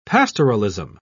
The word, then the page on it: pastoralism: British English